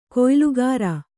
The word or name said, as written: ಕುಯ್ಲುಗಾರ